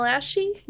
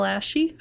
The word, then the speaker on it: Asci: You can check me out on my Instagram at Michelle Ashy. – Michelle Ashy. How do you spell that? – A-S-C-I. – Boy, that's a fascinating last name. – It's Italian.